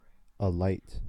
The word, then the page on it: alight: American English